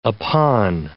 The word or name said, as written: upon